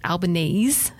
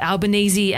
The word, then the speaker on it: Albanese: Albanese, which should actually be pronounced Albanese, he happily accepts the Aussie version of Albanese and we're going with it, was raised by his single mother, Marianne.